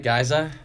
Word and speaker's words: Giza: Did I say that right, Giza? – Yes. – Very good. So in case you guys have met Sean before, now you'll know how to pronounce his last name.